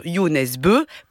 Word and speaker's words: Jo Nesbø: Il est signé de Jo Nesbø, alors ça s'écrit Jo Nesbø, oui. – Mais ça se prononce Jo Nesbø parce qu'il est norvégien.